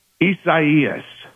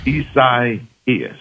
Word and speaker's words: Isaias: Isaias. – Okay, that makes sense. – Isaias.